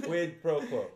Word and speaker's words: quid pro quo: A little bit of pro quo. Did I say that right? – I'm sure you did. – Quid pro quo.